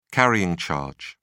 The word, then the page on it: carrying charge: British English